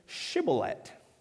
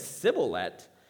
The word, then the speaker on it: Shibboleth: And if he said no, they would tell him to say Shibboleth. Now, if he said Sibboleth, because he could not make his mouth pronounce it right, they took hold of him and killed him on the spot at the Jordan crossing.